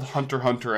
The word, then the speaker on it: Hunter x Hunter: Yeah, where they're just called Hunter Hunter and Spy Family. You don't pronounce the cross in it.